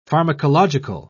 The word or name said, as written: pharmacological